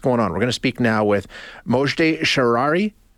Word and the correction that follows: Shahriari: Uh, no, Shahriari.